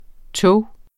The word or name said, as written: tog